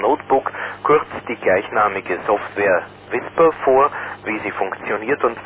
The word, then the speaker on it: WSPR: Der Ihnen bereits von interessanten Vorträgen bekannte Diplom-Ingenieur Hans Meyer, OE1 SMC, wird über WSPR, also Weak Signal Propagation Reporter, ausgesprochen WISPR, referieren.